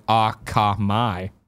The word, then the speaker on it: Akamai: No, man, there's Jorge says I mispronounced Akami. It's actually Akamai.